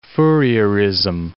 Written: Fourierism

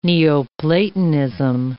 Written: Neoplatonism

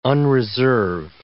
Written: unreserve

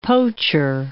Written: poacher

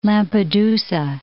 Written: Lampedusa

